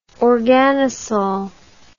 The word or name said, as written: organosol